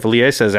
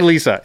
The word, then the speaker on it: Liesa: That doesn't sound right, but it can't be Liesa. It cannot be Liesa. – It's got to be Liesa. – It can't. What's the E doing? – Just hanging out. – It's a silent E.